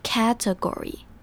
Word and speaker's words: category: 接着我们来看 category 这个字，category 的第一个重点在它的重音在第一音节，所以是 category。